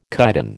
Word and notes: chitin: American English

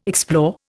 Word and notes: explore: Indian

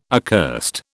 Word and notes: accursed: British English

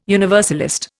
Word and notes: universalist: Indian

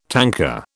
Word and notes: tanker: British English

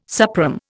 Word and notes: suprême: Indian

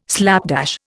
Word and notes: slapdash: Indian

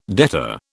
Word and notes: debtor: British English

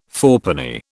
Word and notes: fourpenny: British English

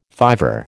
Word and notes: fiver: American English

